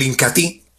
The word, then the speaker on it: Incatea: Y hablando de los productos líderes de la aldea Ramazón, encontramos el Incatí. Se escribe Incatea, pero se pronuncia Incatí.